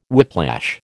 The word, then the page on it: whiplash: American English